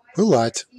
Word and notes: flame: /ˈfleɪm/

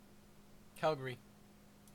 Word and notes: Calgary: /ˈkæl.ɡə.ɹi/

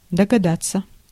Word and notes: догадаться: /dəɡɐˈdat͡sə/

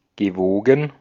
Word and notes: propice: France; [pʁɔ.pis]

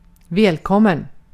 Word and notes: welcome: /ˈwɛl.kəm/

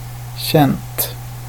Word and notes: känt: ˈɕɛnt

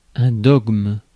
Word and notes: dogme: France

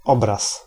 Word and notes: image: /ˈɪm.ɪdʒ/; [ˈɪm.ɪdʒ]

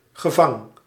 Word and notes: prison: [ˈprɪzən]; American English

